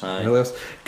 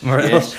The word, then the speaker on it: Morelos: I'm assuming that's how it's pronounced. – Morelos. – Gary Mackay, Stephen, and Gary Harkins. Johnny Clark has gone for Delcio Morelos. How am I? – Yes. – Morelos. – Yes.